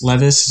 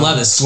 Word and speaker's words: Levis: Is it it's not Levi's. Levis, is that how you say it? – Oh, Will Levis.